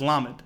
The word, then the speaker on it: lamed: So that's what, and so the Hebrew letter for L is lamed. That's the sound. That's the letter that makes a L sound.